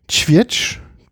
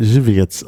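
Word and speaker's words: Żywiec: Ja, Tschwietsch. Tschwietsch heißt, wird es ausgesprochen. Und das habe ich gekauft, weil mich das Etikett angesprochen hat. Das ist wohl eine Brauerei, die gibt es seit 1856. – Ich hätte Zivietsch ausgesprochen. – Zivietsch oder Zivietsch. – Du musst genau. Ja vorne betonen. – Ja, stimmt, Zivietsch.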